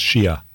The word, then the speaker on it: Xiuh: Her last name is spelled X-I-U-H, but I believe it's pronounced Shia. Letizia Shia from Cusco in Peru.